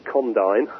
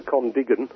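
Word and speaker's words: Condign: Lots of people don't know how that word is pronounced, but it's my understanding it's Condyne, as opposed to Condigan, or a little bizarre. – That's the American pronunciation, I think, Condigan.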